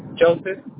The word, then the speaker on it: Joseph: Joseph. – Johnson? – J-O-S-E-H. – J-O-S-E-H. So, pronounce it for me. – J-O-S-E-P-H. – P-H. J-O-S-E-P-H. So, Joseph. Ricardo Joseph.